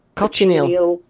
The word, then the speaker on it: cochineal: Cochineal. – Carminic acid and carmines, yeah. – So this is a natural red colour obtained from egg yolk and dried insects. – Yes, it's the beetle, cochineal beetle.